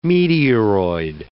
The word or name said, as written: meteoroid